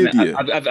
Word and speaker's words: Avdija: Denny Avdija.